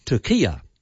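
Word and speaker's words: Türkiye: The new spelling has turkey with an umlaut over the U, and then it goes R-K-I-Y-E. And this new spelling is pronounced Turkia.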